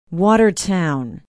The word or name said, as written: watertown